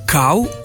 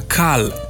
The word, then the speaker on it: kal: Odgovor na vprašanje deloma ponujajo priročniki, in sicer slovenski pravopis, ki pravi: kav v pomenu mlaka bi dejansko brali kav, medtem ko sta v pomenu zelena kal, kal krompirja, klica in podobno možni obe obliki izgovorjave, kal in kav. Dejstvo je, da je na podlagi pravil torej tudi izgovorjava kao pravilna,